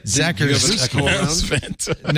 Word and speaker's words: Zakrzewski: Credit to the Washington Post's Kat Zakrzewski. That's pronounced Vanta. – Does it still say it? – Zakrzewski.